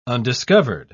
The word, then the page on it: undiscovered: British English